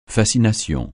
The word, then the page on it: fascination: francais